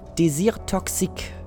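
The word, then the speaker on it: Désir Toxique: Und ähm, meine Nummer 3 aus dem Hause Mikalev ist äh, Desir, Desir Toxique. Das muss man ein bisschen französisch aussprechen,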